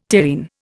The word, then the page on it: terrene: Indian